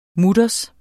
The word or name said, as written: mutters